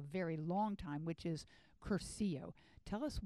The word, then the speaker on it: Cursillo: And the word Curcio, it's spelled C-U-R-S-I-L-L-O.